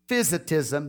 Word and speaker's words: monophysitism: monophysitism, there we go, monophysitism is how you say it, or monothelitism, that Jesus had one nature dominated by divinity.